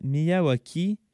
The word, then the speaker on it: Miyawaki: Oui, est-ce que je l'ai bien prononcé? – Absolument. – OK, parfait. Bon, j'étais pas sûr de la prononciation, mais tant mieux. justement, la méthode Miyawaki, voulez-vous nous en parler pour ceux qui connaissent moins ça? – Absolument.